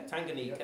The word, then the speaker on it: Tanganyika: I think I pronounced that, Tanganyika? Yeah, Tanganyika. Yep. And he,